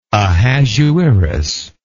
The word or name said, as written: Ahasuerus